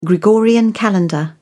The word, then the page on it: gregorian calendar: British English